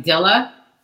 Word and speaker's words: Jeli: her name is Jeli, but it's pronounced like D-I-L-A, like Dilla.